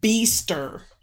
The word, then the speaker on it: Beester: It's Beester. – Spell it, please. – Like B-E-E-S-T-E-R.